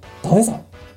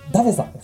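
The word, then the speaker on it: だべさ: ダベさん。はい。今、発音おかしかったね。ダベさんですね。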